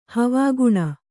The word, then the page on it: ಹವಾಗುಣ: havāguṇa